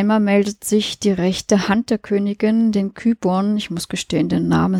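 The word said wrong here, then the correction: Qyburn: Quiburn heißt er übrigens, wird in der Serie ausgesprochen.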